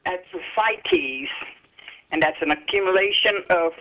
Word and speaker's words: ascites: Ascites.